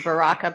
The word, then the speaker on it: Baraka: It's Baraka, but you're supposed to roll your R's, you know, like, and I can't do it.